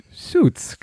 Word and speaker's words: Suits: Hier dieses Suits. Suits, genau.